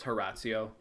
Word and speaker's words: Horatio: That's like how it's spelled, but it's Horatio.